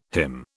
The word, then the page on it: HIM: British English